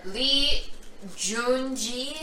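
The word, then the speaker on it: Lee Joon-gi: How do you pronounce the G-I? Is it like G? – Yeah. – So, Lee Joon-ji? – Yeah.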